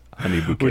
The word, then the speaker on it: Honey Bucket: It's spelled Honey Bucket, but pronounced Honey Bouquet.